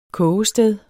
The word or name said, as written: kogested